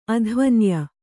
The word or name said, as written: ಅಧ್ವನ್ಯ